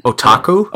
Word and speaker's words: otaku: Otaku? – Otaku, that's correct. Good pronunciation, okay, by the way.